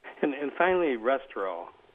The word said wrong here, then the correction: resveratrol: Was it? I mispronounced that probably. Restorol, or the grape, or whatever. – Oh, resveratrol.